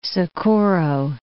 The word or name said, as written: Socorro